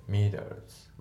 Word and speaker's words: meters: であと問題はその後ろの Meters、とりあえずメーターっていう風に発音しないで Meters、あと T が少し濁って Meters っていう風に普通は発音するんだけど、この人の発音が Meters、 Meters みたいな感じになってますよね。